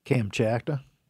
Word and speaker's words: Kamchatka: You know the Cam, how do you pronounce that peninsula? I've never been there. I'd like to go there. Kamchatka? – Kamchatka. – I think it's that. – Yeah, Kamchatka. Kamchatka?